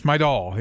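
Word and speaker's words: Mejdal: It sounds like he's bringing Sig Meidahl with him. Meidahl, is that how you say it? I've been told it's because it's not spelled that way, but it's Meidahl.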